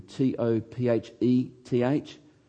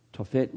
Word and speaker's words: Topheth: It's, it's written T O P H E T H. Tophet looks like Topheth, but it's pronounced Tophet.